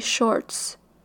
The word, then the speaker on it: Shorts: Les Reels et les Shorts. Bonjour, c'est fait du montage. La manière que j'ai prononcé ça m'insulte tellement. Ce sont des Reels et des Shorts pas des real short, sont la version hors retard des TikTok.